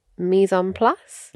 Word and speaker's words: mise en place: and I've been trying to implement the mise en place.